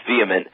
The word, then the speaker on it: VHEMT: So VHEMT, pronounced vehement, is a movement, not an organization.